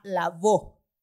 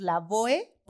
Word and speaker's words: Lavoe: Entonces, las dos, encontré que las dos pronunciaciones son correctas. Si la gente le quiere decir la boe, porque al final es con e, está bien. Normalmente los que somos latinos le decimos la boe,